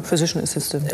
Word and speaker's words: Physician Assistant: Du bist Studentin im Bereich Physical Assistance. Ist das richtig ausgesprochen? – Genau, Physician Assistant.